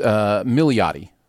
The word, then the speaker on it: Milioti: And there's another syllable in there. I think it's Milioti, I think is what it is.